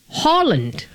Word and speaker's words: Haaland: The very native way of pronouncing his name is Erling Haaland. So when you see a double A in a Norwegian name or in the Nordic language, it's actually kind of pronounced as an O.